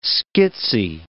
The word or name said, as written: schizy